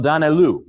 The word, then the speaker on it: Daniélou: If you want to spell that, just write the word Daniel and they just put an OU at the end of it. Cardinal Daniélou, right?